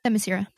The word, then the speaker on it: Themyscira: Themyscira.